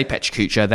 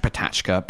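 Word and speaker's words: PechaKucha: I say Petra Kuchas. They're actually pronounced Patachka.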